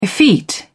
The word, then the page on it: effete: British English